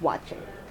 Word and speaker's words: waakye: Yes, W A A K Y E. – Got it. – Yeah, but it's pronounced watching.